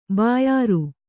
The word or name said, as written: ಬ್ಯಾರ